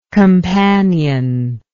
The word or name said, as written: companionless